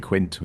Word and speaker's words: Quint: They don't really pronounce the T in the film. They say Quinn, but it was actually Quint was his name.